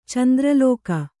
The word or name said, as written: ಚಂದ್ರಲೇಖೆ